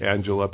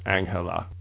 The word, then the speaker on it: Angela: It looks like Angela, but I usually hear it pronounced Angela.